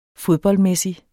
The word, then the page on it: fodboldmæssig: -ˌmεˀsi; -ˌmεsi